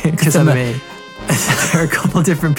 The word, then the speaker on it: Kissimmee: they have a control center in Kissimmee, and there are a couple different people responding." – That's how you can tell the tourists. They call it Kissimmee, but it's really... – Whatever, man! – It's Kissimmee.